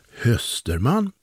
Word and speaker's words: Österman: Den ena heter givetvis Österman, uttalas Hösterman, och den andra säger "Fryser du, Hösterman?"